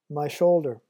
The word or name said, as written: my shoulder